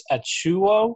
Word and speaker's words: Achiuwa: Precious Achiuwa.